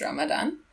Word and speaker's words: Ramadan: In Arabic countries, it's pronounced Ramadan. But in Pakistan, where the predominant language is Urdu, the word is Ramzan or Ramazan.